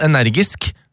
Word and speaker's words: energi: Energi skal uttales på samme måte som geni, med velgelsen Stian I Bergen.